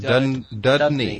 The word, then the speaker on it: Dudney: How do you say it? Dudney. – Yeah. – Like a gotcha.